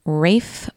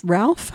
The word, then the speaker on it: Ralph: Rafe Fiennes. Who is Ralph Fiennes? – No, it's pronounced Rafe, but it is spelled Ralph.